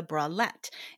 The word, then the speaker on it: bralette: All right, the first style I want to talk about is the bralette or the bralette. It's up to you how you say it. Both ways are correct.